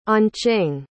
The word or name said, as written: anqing